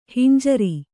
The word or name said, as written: ಹಂಜರ